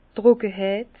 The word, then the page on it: d' Trockeheit: Bas-Rhin; Herrlisheim